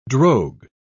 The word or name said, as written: drogue